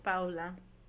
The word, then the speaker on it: Paola: You don't hear Paola. Paola. Oh, you're saying that's how you say it in Spanish. – Uh-huh. Right? – Oh. No, I think Paula and Paola are two different names. Oh, yeah, you're right. Paola.